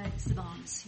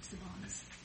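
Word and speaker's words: Szbanz: Sabans, he pronounces it Sabans.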